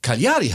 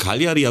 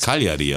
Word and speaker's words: Cagliari: Cagliari heißt's. Okay, ich hätte gesagt Cagliari, aber ich Nein, bin auch kein Stadionsprecher.